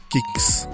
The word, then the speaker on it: QIX: Otro juego que tuvo relevancia, a lo mejor no fue tan famoso o popular, fue el de Kix, lanzado en 1981, que QIX, así es como se escribe, QIX, Kix.